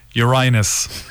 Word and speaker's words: Uranus: No, say it properly. – Uranus. Happy now? – Yep.